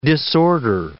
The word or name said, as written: disorder